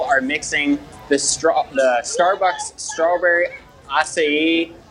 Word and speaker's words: acai: How is that pronounced? The A-C-A-I berry? – Acai. – Acai. – Yeah. – I would say acai. – Yeah, me too.